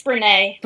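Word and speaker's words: Brené: It's Brene. Brene Brown.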